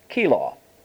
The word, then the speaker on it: Keilah: Then they told David, saying, Behold, the Philistines fight against Keilah. And that's how you pronounce that, by the way.